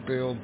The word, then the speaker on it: Bill: My name is Bill Bravo India Lima Lima, and I'm on here quite frequently.